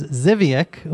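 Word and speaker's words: Żywiec: I read it all the way to the final word, which is Zivyek, which is spelled Z-Y-W-I-E-C.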